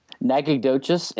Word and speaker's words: Nacogdoches: Nacogdoches, and they are the sister city of a place in Louisiana called Natchitoches, which is spelled even crazier.